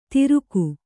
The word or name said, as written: ತಿರುಕು